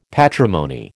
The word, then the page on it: patrimony: American English